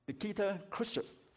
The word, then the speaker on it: Nikita Khrushchev: Nikita Khrushchev，我查過 Google 是咁樣嘅發音嘅，克魯曉夫，大家可能都聽過呢個人物，係啊，佢係由 1953 年到 1964 年蘇聯共產黨嘅第一書記。